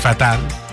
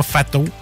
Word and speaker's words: fatals: Fatal, mais ça se prononce fatal, c'est pas fato.